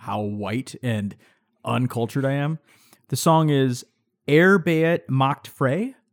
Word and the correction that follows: Arbeit macht frei: Yeah, Arbeit macht frei.